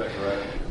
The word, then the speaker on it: Zechariah: Zechariah.